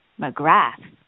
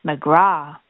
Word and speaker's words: McGrath: But in fact, the TH at the end is silent. So the correct way to say my family name is McGraw, with a sort of drawn-out ah at the end, like you've just taken a good refreshing drink of water. This is the Irish pronunciation.